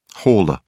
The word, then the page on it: hauler: British English